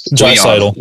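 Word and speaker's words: Draisaitl: A lot of people are saying, okay, McDavid and whatever his name is, Dreisaitl. – Dreisaitl. We're gonna. I always have trouble pronouncing his last name. I don't know why. I want to say Dreisaitl. Yeah, okay. Dreisaitl.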